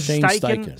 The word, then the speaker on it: Steichen: Steichen, isn't it? Steichen.